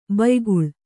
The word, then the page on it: ಭಯಾಕುಳೆ: bhayākuḷe